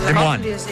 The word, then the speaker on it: Des Moines: Des Moines. – Des Moines, capital d'Iowa. – No et distreguis tu, que estàs segut. – Des Moines. – I després hi ha també... – Sí, perquè es pronuncia francès.